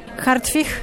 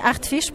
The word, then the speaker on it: Hartwich: Hartwig, c'est en polonais, en français Hartwig, pourquoi pas.